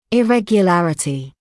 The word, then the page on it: irregularity: [ɪˌregjə’lærətɪ]; [иˌрэгйэ’лэрэти]